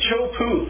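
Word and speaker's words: Teahupo'o: It's called Chopu. That's the way you pronounce it. Looks like Tehupu, D-E-A-H-U-P-O. Look it up.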